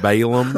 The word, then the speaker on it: Balaam: And Balaam. – Balak and Balaam. – Oh. – Yeah, I was almost ready to interject and say, for all of us poor English speakers, that would be Balaam.